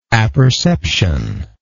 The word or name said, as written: apperceptively